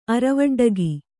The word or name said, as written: ಅರವಡ್ಡಗಿ